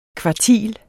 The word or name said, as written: kvartil